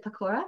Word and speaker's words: pakora: Pakora, is it pakora? – Pakora. – Yes. – Pakora? – Yeah. You're bang on.